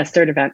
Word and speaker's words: Sturtevant: And you have a new book out, Reclaiming Body Trust, A Path to Healing and Liberation, written with Dana Sturdivant. Did I say that right? – Yeah, Sturdivant.